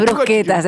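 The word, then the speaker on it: bruschetta: La de brusquetas, es brusqueta, pero se escribe bruschetas. – A la brucheta.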